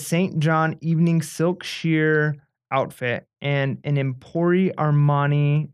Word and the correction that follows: Emporio Armani: am I saying that right? Emporio Armani yes.